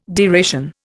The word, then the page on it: deration: Indian